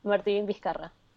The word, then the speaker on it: Vizcarra: Yes, Martin Vizcarra.